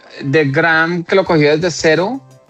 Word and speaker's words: Graeme: Ya no va a estar más, no, mire, Graeme Lowton, no sé, el nombre Graham, se pronuncia Graham Lowton, que ese era el team principal de Cadillac, el que recibió el equipo prácticamente.